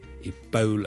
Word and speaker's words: Ebola: 最近如果大家有看國際新聞，都應該會留意到 Ebola 這個伊波拉病毒在某些地方肆虐。 Ebola 伊波拉病毒，我們雖然廣東話我們譯成伊波拉波啦，我們是 O 這個發音，但如果是英文，我們通常是讀 Ebola 的 Ebola Bo Bo，好像弓箭的弓那個 Bo、 okay?